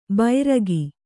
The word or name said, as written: ಬೈರಗಿ